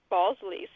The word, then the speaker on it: Balsley: Balsley.